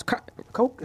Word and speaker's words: Koch: Koch, is that how you say it? – Yeah, Koch.